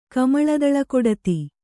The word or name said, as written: ಕಮಳದಳಕೊಡತಿ